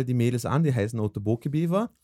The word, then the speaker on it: Otoboke Beaver: Oto, also O-T-O-B-O-K-E. – -E. Und Beaver, so wie, wie Englisch. – Genau, Beaver sowie Bieber auf Englisch, genau. Otoboke Beaver. Otoboke, oder sie sagen Otoboke Beaver.